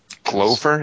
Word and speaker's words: Glover: Glover? Is that how you say his name? – Yeah, Crispin Glover. – Because it rhymes with Clover.